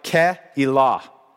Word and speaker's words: Keilah: This word, Keilah, it's actually pronounced something like Keilah.